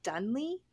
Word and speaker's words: Donlea: I Googled him and I did the pronunciation thing so that I would make sure I didn't say it wrong. And it's Donlea.